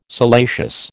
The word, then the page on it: salacious: American English